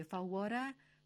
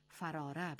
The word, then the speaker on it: فواره: این کلمه در اصل فواره است، یعنی فواره چون بلند شود سرنگون شود، ولی بسیار شنیده شده است که در زبان عامیانه به جای فواره فراره به کار می رود.